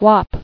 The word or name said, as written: Whop